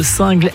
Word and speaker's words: Singles: Allez, on file du côté de Singles, S-I-N-G-L-E-S, on ne prononce pas single.